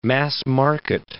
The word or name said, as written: mass-market